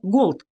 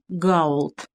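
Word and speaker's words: gold: как просто произносит мистер Ирвинг слово «голд» вместо распространенного «гаулд».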